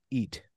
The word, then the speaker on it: Eet: It's spelled E-E-T, Eat.